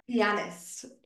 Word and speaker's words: pianist: The word, it's so similar. Pianist. But that's not right. That's not how you say it.